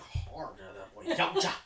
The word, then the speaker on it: Yautja: Got pronounced the T in there. Yautja. – Were they Japanese? – No. Actually, the T is kind of silentish.